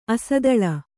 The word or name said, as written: ಅಸ್ಥೂಲ